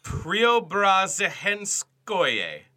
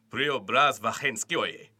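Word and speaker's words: Preobrazhenskoye: You just have to say it like really low in your register and from the back of your throat. – Priobrazhenskoye.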